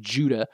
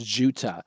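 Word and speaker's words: Juttah: It's J-U-T-T-A-H. I'm probably going to pronounce that like Judah, and it's going to sound like J-U-D-A-H, Judah, but it's not, it's Jutah.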